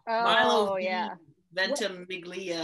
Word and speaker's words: Ventimiglia: He was on Milo Ventimiglia.